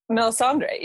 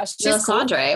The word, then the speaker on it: Melisandre: How do you pronounce this? Some Game of Thrones. Melisandre, yeah. – Melisandre. Cool.